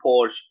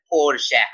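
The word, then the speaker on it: Porsche: اینقدر همه جا گفتن پرش پرش پرش که پارسال و پرش اومد ویدئوی یه آفیشال داد تو یوتیوب گفت که آقا جون من تلفظ درست این برند پرشه هست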